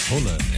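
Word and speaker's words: Haaland: A pronúncia dele mesmo no norueguês é outra, você sabe, né? – Holland. Holland.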